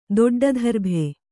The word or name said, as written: ದೊಡ್ಡಧರ್ಭೆ